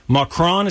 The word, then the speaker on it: Macron: Macron, is that how you say it? – Oh, yeah.